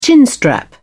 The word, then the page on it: chinstrap: British English